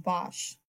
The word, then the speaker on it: Bosch: I'm sorry, I mispronounced his last name, Bosch.